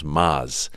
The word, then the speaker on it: Maz: To go, listen, man, not a big deal, but just, it's actually pronounced Maz. – Right. – And then I go through the whole thing. I go, it's like Mazda, Mazatlan, Maserati, Lamaz.